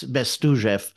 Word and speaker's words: Bestuzhev: And just learning how to pronounce, like, ZH is the Z sound in a, in a word like Zhazha. It's Bestuzhev.